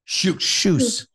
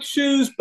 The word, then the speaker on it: Kjus: It's like shoes, but with a SS at the end. But it's pronounced KJUS, spelled KJUS, because the Norwegians don't pronounce a K and a J. They pronounce it as an SH.